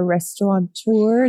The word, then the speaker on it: restaurateur: I didn't know you were a restaurateur. Is that the right way to pronounce that? – Yes, yes, yes.